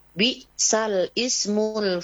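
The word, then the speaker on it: bi'salismul (بئس الاسم): Secara praktik itu ada di surah Hujurat, ya, Al-Hujurat ayat 11, ya, pada kalimat bi'sal ismul fusuku ba'dal iman. Bi'sal ismu dibaca bi'salismul, jadi hilang hamzahnya, ya, dibacanya bi'salismul fusuku ba'dal iman. Nah, ini bacaan-bacaan yang muskil, ya, mungkin yang termasuk yang tidak sesuai dengan tulisannya, bacaannya.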